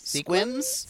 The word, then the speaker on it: Sequim: Squims? – Squinton? – It's spelled S-E-Q-U-I-M, but he says it Squim, Washington.